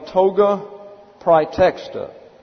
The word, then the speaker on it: praetexta: And so we have the toga praetexta. P-R-A-E-T-E-X-T-A.